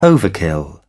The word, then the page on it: overkill: British English